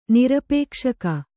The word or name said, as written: ನಿರಪೇಕ್ಷಕ